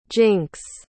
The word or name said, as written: jinks